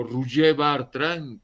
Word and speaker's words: Roger Bartra: con motivo de los 80 años de Roger Bartra o Roger Bartra, como se dice en catalán, como se pronuncia en catalán.